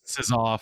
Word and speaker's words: Czar: Did I say that right? Is it SAR? It is, right? – That's the one. – Well, like the card SAR from Cards Against Humanity.